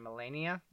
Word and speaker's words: Melania: And his wife or his fiance at the time, Melania, is that how you pronounce it? – Uh-huh.